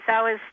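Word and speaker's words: Ohsawa: She has studied with the top microbiology teachers and graduated from Lima, I might mess up this one, Ozawa Academy in Japan. Is that correct? How you pronounce it? – Ozawa. The Ozawa started the whole movement, necrotic movement, which eventually came over here.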